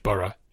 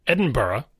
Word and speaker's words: Edinburgh: So one is Edinburgh, where it's B-U-H-R-U-H, Burra, Edinburgh.